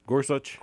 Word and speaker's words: Gorsuch: Gorsuch.